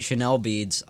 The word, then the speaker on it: Chanel Beads: Before that was Chanel Beads, I want to say. That's how you say it. That's how you say it. Chanel Beads, Drums Only, opening track off of their new album, Your Day Will Come.